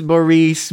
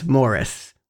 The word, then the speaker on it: Maurice: It actually is Maurice, but everyone, because of their accent, pronounces it as Morris. It's just a different emphasis. It's Maurice, I guess.